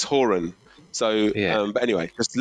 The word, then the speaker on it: Horan: because the Americans behind me were pronouncing it Haran, right, like Quran, and I was like, oh, and it's spelled Horan, and I was like, well, that's like Horan,